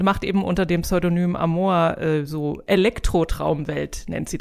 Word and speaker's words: Amoa: Ähm, wir fangen an mit Amoa so, glaube ich, spricht man das aus.